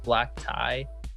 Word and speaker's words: Blaqti: So my name is Jake, and I produce under the name Black Tie, and it's spelled B-L-A-Q-T-I, which is confuses everyone.